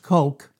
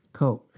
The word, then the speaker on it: choq: It's pronounced koke. You would pronounce it like K-H-O-K-E, but it's spelled C-H-O-Q, koke.